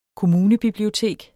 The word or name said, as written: kommunebiblioteket